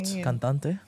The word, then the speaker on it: cantante: Cantonante? How do you say singing? – What? Cantante? – Cantante? – Yeah.